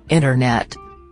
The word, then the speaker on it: internet: Quando aparece um N e T juntos, você não fala o T. O exemplo melhor é a palavra internet, que em inglês você fala internet.